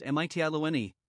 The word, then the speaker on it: Mytilene: Sailing south along the western coast of Asia Minor, they first came to Mytilene, pronounced Mytiloene, the chief city of the island of Lesbos.